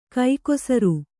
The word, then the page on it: ಕೈಕೊಸರು: kaikosaru